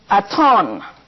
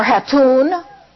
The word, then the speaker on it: Hatoon: It is pronounced Aton. I don't like it pronounced Aton or Hatoon. That's the way it's usually spelled. H-A-T-O-O-N, Hatoon. It isn't Hatton. It's Aton. Therefore, in Egyptian, the nearest thing you have to spelling my name is H-A-T-O-N-N.